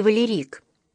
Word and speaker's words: Валерик: В этом же стихотворении он рассказывает о жестоком бое с чеченцами у реки Валерик с ударением на последнем слоге, эпизоде той бесконечно долгой 70-летней войны, которую вела Россия в XIX веке на Северном Кавказе.